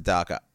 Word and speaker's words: DACA: They pronounce it DACA. It's not a real word. You can pronounce it however you want.